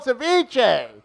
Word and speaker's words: ceviche: I go, it's called ceviche. You guys haven't been able to say the name right.